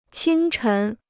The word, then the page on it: 清晨: qīng chén